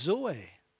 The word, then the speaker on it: zoe: But it's zoe. Z-O-E, pronounced zoe.